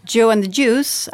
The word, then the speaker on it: Joe and the Juice: Och eh, man kan säga att startskottet till det här, det som verkligen fick eh, riskkapitalisterna att eh, vakna, det var Dewey and the Juice. Känner du till den kedjan? – Joe and the Juice, absolut.